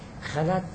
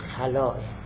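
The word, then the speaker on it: خلاء: البته خلا که ما تعبیر میکنیم، اینو شاید حالا بعدن هم عرض میکنم، خلا تعبیر غلطیه، تعبیر درست خلاه. منتها چون ما فارس ها خلا رو به معنی دیگر هم تطبیق میکنیم، لذا سعی کردیم خلا تعبیر کنیم، تلفظ کنیم، ولی تلفظ صحیحش خلاه.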